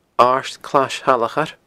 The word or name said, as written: Aird Clais Shalachair